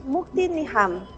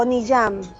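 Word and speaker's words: Mukti Nijam: Y se escribe M-U-K-T-I latina N-I-J-A-M, Mukti Niham o Niyam, es correcto de ambas formas.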